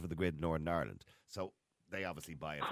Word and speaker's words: Northern Ireland: You don't pronounce the T. Northern Ireland.